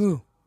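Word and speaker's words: MØ: Mo era, ¿no? ¿Qué se pronuncia? – Mu. Mu, Pero no importa, no te iba a corregir.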